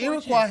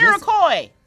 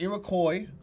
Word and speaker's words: Iroquois: The Iroquois had conquered them. – No, Iroquois. – See what I'm saying? That's why I want to read it again. – You got to say it right. – The Iroquois.